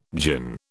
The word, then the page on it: djinn: British English